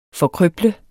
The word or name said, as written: forkrøble